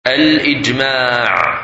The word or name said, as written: Ijma